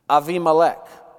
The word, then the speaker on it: Abimelech: From this arrangement, a son was born, whom Gideon named Abimelech. Well, that's how we say it in Texas. That's not how a Hebrew would pronounce it. Avimelech. Av, father. Avi, my father. Melech, king. Literally translated, my father is king.